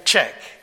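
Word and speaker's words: Zschech: And one of the songs that I put on my Spotify list was You Are Holy, Darlene Zech. Is that how you pronounce her name? Czech. Okay, Darlene Czech.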